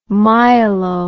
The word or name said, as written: myelo